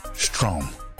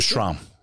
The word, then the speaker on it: Strom: And I am very proud to announce Christopher Strom.